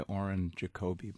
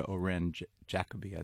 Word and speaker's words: Oren Jacoby: Back in the States, I go by Oren Jacoby, but I'll answer to Oren Jacoby. I think that sounds even better.